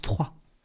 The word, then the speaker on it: Troyes: La ville se prononce Troyes.